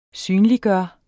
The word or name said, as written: synliggøre